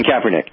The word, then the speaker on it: Kaepernick: All right, so in terms of the legalities of Colin, I don't pronounce this guy's name, Kaepernick, I guess that's... – Yeah, Colin Kaepernick.